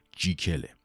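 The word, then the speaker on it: Jekyll: تلفظ واقعی جکیل که در زبان اسکاتلندی ریشه داره جیکله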